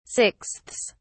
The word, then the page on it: sixths: British English